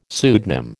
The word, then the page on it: pseudonym: American English